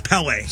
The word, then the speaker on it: Pelé: Did you ever see that Pelé movie, too? When you're mispronouncing it every time. It's Pelé.